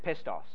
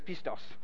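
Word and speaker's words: pistos (πιστός): And I did check the pronunciation of that, and that's right. Would have been easier if I said pestos, but it's pistos.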